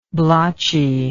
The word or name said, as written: blotchiness